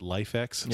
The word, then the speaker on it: LIFX: I have bulbs from a Hue competitor called I think they pronounce it LifeX, yes. – Spelled LIFX.